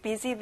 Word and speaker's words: busy: 친구들, S 발음은 Z 발음이랑 똑같이 나요. Busy.